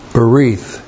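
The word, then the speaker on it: Berith (בְּרִית): That's B, Shiva, R, long I-T-H. Berith.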